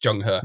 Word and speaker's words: Zheng He: And actually what we actually ended up doing was following the footsteps of the famous Chinese explorer 15th century Zheng He, which is spelt Zheng He. So if you're English, you might recognize it, pronounced Zheng He.